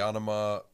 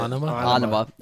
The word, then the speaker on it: Anima: Three, Anima.